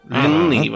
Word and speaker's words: Линней: Серьезный прорыв совершил шведский ученый Карл Линней. Линней, вообще правильно по-шведски. – Да. – На последний слог ударения он как бы Линне пишется, по-шведски получается Линней.